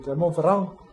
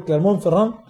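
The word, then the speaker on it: Clermont-Ferrand: Clermont Ferrand le ganó 27-13. – Ah, me gustó, me gustó esa pronunciación. – Vendría a ser Clermont Ferrand.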